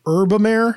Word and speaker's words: Herbamare: We called it Herbamare. – Oh, Herbamare. – But it might be like Herbamare. H-E-R-B-A-M-A-R-E.